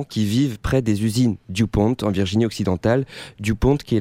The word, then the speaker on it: DuPont: DuPont, prononcé DuPont.